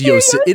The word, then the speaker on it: Yosemite: No, it is Yosemite. It is Yosemite. That is the way that they say it, but it's bullshit. Look at the way it's spelled.